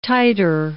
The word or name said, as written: titer